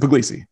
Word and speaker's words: Pugliese: How do you say your full name, quote unquote, properly in your world? – Vincent Puglisi, my proper.